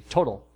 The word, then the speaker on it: Total: T-O-T-A-L. – Okay. – So to us in America, it would be Total. – Okay. – But the French pronounce it Total.